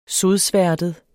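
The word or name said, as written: sodsværtet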